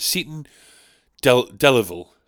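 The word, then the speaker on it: Seaton Delaval: Seton Delaval. Seton. It's called Seton Delaval, okay? But friend of mine once pronounced it Seton Delaval, as if it's like some fucking village in Spain.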